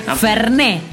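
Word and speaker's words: Fernet: Sí, Ferné. – Así, con acento en la E a pleno.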